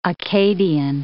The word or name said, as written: Acadian